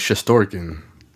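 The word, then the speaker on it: Shesterkin: I believe it's Shestorkin.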